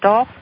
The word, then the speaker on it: dulse: It's worthwhile going to his site and looking at that information because he has things like seaweed, purple, I guess you pronounce that dulse, D-U-L-S-E, seaweed, cherries, palm fruit.